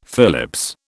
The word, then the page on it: Phillips: British English